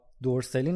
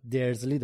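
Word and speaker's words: Dursley: علاوه بر هاگوارتس کلی دیگه از اسامی کتاب هم تغییر کرده، تلفظا دقیقا مثل نسخه بریتانیاییه، یعنی ما دیگه هرمیون نداریم، هرماینی داریم، یا دورسلی نداریم، درزلی داریم و کلی اسامی دیگه.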